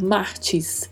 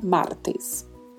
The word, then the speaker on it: martes: O R vibrato, que já comentamos inúmeras vezes nos nossos podcasts, e o TE, que pronuncia-se também sempre T. T. Não existe a pronúncia ti no espanhol. Então não podemos falar martes, não. Em martes, não. Em martes. Martes. É sempre T com esse E fechado, tá? Martes.